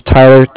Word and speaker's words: Tyler: My name is Tyler, T-Y-L-E-R, and like I said, I'm calling from Cardiff. It's spelled C-A-R-D-I-F-F, as in Frank.